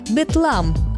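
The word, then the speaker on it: Bethlem: Название «Вифлеем» в английском произношении звучит примерно так – «бетлам».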